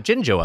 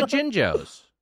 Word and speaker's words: Jinjo: Is it pronounced the Jinjo? Jinjo? – It's a J. It's not a G. It's not a G. – Oh, it's a J. – It's a J. Yeah, it's J I N J O.